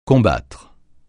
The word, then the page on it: combattre: francais